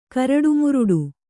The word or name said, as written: ಕರಟುಮುರಟು